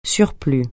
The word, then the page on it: surplus: French